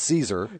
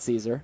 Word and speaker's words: Szczur: He says, how do you pronounce Matt S-Z-C-Z-U-R on the Cubs? More importantly, how does Herc pronounce it? – And I have the answer to both questions. – Okay, go ahead. – All right. Well, of course, it's pronounced Caesar. – Yes, Matt Caesar.